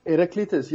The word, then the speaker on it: Heraclitus: Heraclitus. – From the Stoics. – Yeah. How do you pronounce his name? – Heraclitus, yeah.